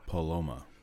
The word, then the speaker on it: Paloma: the Palomo. Is that how you pronounce it? – The Paloma.